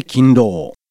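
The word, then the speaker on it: 金狼: 金の狼と書いて勤労。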